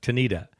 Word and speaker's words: Tanida: The Japanese team of Tanada Yamamoto. Did I say that right? – Yasumasa Tanida. Yeah, that's the way you say it.